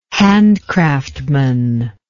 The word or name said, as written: handcraftman